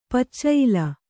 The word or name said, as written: ಪಚ್ಚಾಯ್ಲ